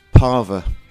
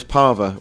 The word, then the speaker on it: Parva: Parva. – Parva, how are you spelling that? – I spell it P-A-R-V-A. – Or like Palmer, but with a V.